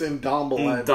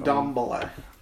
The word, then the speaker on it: Ndombele: I'm getting a Dumbele. How do you say that? – Dumbele? – Yeah, that's good enough. – Dumbele? – He's not... – I believe it's Ndombele, I was giving it to you.